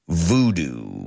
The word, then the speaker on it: voodoo: No boo con B-O-O, sino voodoo. So nosotros lo escribimos V-O-O-D-O-O y lo pronunciamos voodoo, voodoo.